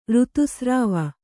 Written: ಋತುಸ್ರಾವ

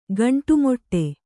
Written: ಗಂಡುಮೆಟ್ಟು

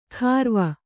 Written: ಖಾರ್ವ

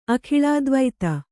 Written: ಅಖಿಳಾದ್ವೈತ

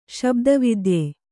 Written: ಶಬ್ದವಿದ್ಯೆ

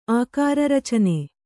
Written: ಆಕಾರರಚನೆ

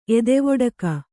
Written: ಎದೆವೊಡಕ

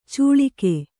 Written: ಚುಳಿಕೆ

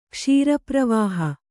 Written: ಕ್ಷೀರಪ್ರವಾಹ

